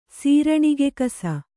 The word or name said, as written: ಸೀರಣಿಗೆಕಸ